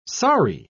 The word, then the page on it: saree: British English